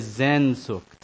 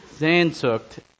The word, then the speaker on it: Sehnsucht: Well, you're going to hate me because I'm going to butcher this, but uh, the word is Sehnsucht. S E H N S U C H T. I think the S is pronounced like a Z.